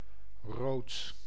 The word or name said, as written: rood